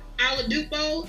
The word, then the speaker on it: Oladipo: I gave him Victor Oladipo.